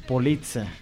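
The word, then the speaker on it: Poliça: Hagan de cuenta que se escribe como Polica, pero en lugar de la C normal que nosotros usamos, se usa la C que usa normalmente el lenguaje portugués.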